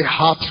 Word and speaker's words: heart: Tell yourself, say heart right. Heart right. No. The way you're pronouncing it sounds like A-R-T.